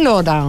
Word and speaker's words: Laurent: Ο Λεσχέν Λοάν. Μεξικάνοι. – Μεξικάνοι. – Το φάγανε. – Ναι, αφού είπα Γαλλία-Μεξικό. – Ναι. – Θα το πούμε προφορά ξανά. Ο Λεσχέν Λοράν.